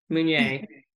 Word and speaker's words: Meunier: Is that how I pronounce your last name? – Pretty close. Yeah. Meunier.